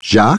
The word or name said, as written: Jacques